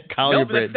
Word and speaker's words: colubrid: Now I'm saying it. Colubrid. – No, that is the correct way to say it. I was told by Warren.